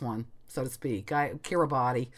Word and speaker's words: Kiribati: Um, it's spelled K I R I B A T I.